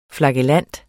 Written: flagellant

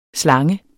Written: slange

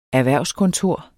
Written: erhvervskontor

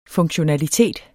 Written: funktionalitet

